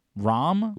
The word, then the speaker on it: Rahm: It's the soft A. It's like Nadal, right? Like, that's how they pronounce those. That's very Spanish. Hebrew origin. – Rahm? – Yeah.